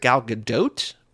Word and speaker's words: Gadot: I I was looking today for Fast and Furious, Giselle, Fast and Furious Gal Gadot, and like it didn't come up. I think also she was on Hot Ones recently. I think she pronounces it Gal Gadot, which is a third pronunciation.